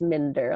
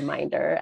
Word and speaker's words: Minder: It's Mender. A lot of people say Minder.